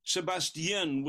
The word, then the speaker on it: Sebestyen: Sebestyen